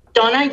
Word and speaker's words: Donna: It's Donna.